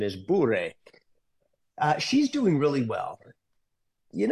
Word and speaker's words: Bure: So let's you and I take a lesson together in Russian pronunciation. It's Bure.